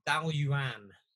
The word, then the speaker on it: Dailuaine: Dalyuan. – Dalyuan. – Dalyuan. It's spelled D-A-I-L-U-A-I-N-E.